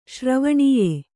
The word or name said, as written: ಶ್ರವಣೀಯೆ